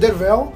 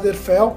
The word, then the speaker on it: Derfel: Cornell conta a história de Dervel, que meu irmão vai falar Derfel, porque ele não quer falar direito, que nunca mandou explicar. É que se escreve Derfel e se fala Dervel.